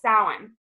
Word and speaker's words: Samhain: And for those of you who don't know, it's spelled S-A-M-H-A-I-N, which is like a hilarious way to spell things, but that's just the way that the Celts pronounce their